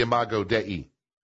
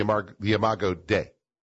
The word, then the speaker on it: Imago Dei: I'm trying to ask my pastor if I said that right. Did I say it right, Pastor? He said yes, that's okay. He would say the Imago Dei. I would say the Imago Dei, the image of God.